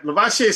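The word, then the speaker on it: Lovasea: Lavassier sort of sounds like that Busta Rhymes song, Pass the Cavassier. I mean, he told me that's how you say it.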